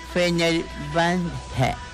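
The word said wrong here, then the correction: Fenerbahçe: Es ándale, Fenerbahce.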